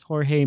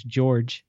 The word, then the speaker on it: Jorge: So we got a new artist on this, Jorge Molina. – Or if you don't like to pronounce the Spanish, George Molina.